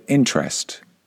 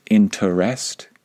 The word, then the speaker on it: interest: Okay, the next word that is commonly mispronounced is the word interest. Now, I think that most native English speakers would pronounce this word with two syllables. Some of them might pronounce it with three syllables, interest, but most of us just pronounce it with two syllables.